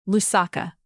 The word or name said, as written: lusaka